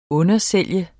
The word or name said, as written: undersælge